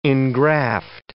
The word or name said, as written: engraft